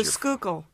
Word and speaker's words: Schuylkill: And this one is from, you're going to have to pronounce the name of that river again because the Schuylkill.